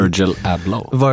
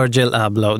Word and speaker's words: Virgil Abloh: Virgil Abloh. – Virgil Abloh.